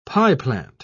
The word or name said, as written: pieplant